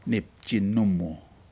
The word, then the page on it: nipji nomu: Aneityum